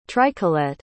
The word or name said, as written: tricolette